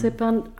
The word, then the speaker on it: Zypern: Oder zum Beispiel Zypern, habe ich das richtig gesagt? – Ja, Zypern.